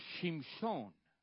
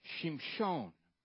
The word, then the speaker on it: Shimshon: In Hebrew, it's pronounced Shimshon. Shimshon.